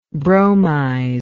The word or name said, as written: bromize